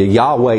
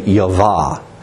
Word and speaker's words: Yahweh: I guess what I'm going to get at to you, Yahweh is the correct English pronunciation, okay? And I think we can be comfortable with that. Some people have liked to say Yavah.